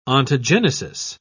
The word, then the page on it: ontogenesis: British English